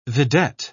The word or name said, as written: vidette